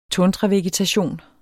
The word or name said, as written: tundravegetation